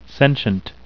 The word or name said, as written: sentient